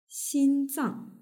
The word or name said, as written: xinzang